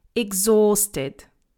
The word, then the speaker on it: exhausted: Exhausted. We don't pronounce the letter H. It's silent, so it's not exhausted, yeah? It's exhausted. There is a long O in there as well.